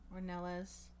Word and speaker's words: Ornelas: Sierra Teller Ornelas, is that how you say it? Ornelas.